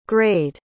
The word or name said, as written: grayed